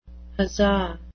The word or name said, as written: huzzah